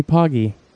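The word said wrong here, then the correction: Page: Oh, Paige.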